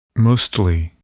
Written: mostly

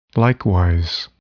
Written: likewise

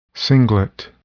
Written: singlet

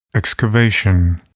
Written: excavation

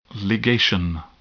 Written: ligation

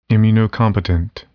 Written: immunocompetent

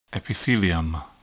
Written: epithelium

